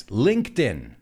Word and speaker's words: LinkedIn: Because everybody, I think, in Spain is pronouncing it wrongly. It's LinkedIn. – My ears are bleeding right now. It's LinkedIn.